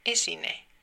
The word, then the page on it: esine: /ˈe̞sine̞ˣ/